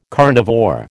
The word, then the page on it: carnivore: American English